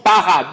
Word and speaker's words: bara: Le mot créa ici est un mot hébreu que l'on prononce bara, B-A-R-A.